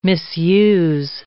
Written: misuse

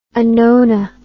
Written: anona

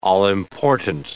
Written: all-important

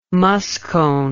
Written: muscone

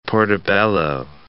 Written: portabello

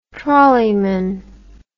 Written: trolleyman

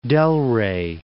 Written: Delray Beach

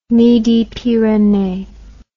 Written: midi pyrenees

